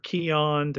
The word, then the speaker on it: Keoghan: Barry Keoghan or Keon, depending on which pronunciation you're going to use here.